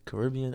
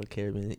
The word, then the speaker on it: Caribbean: Listen, it's pronounced either Caribbean or Caribbean. Either one you say is correct.